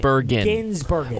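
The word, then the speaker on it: Gisbergen: That plus our thoughts on NASCAR at Chicago and the meaning of the victory for Trackhouse Racing and Shane Van Griesbergen. That still to come. Correct my pronunciation. – Ginsbergen.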